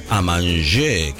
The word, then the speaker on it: Amanché: ¿Cómo se pronuncia? Amanché, que es a comer en francés, por supuesto.